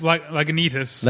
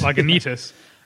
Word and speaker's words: Lagunitas: It's Lagunitas. – Lagunitas, I'm sorry. – Right. It actually has the pronunciation guide on here. – Oh, it does? – Lagunitas.